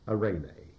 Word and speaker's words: eirene: And the word peace is the Greek word eirene. It's easy to remember because the woman's name, Irene, I-R-E-N-E, comes from that. In Greek, it is spelled E-R-I-E-N-E, E-I-R-E-N-E, eirene.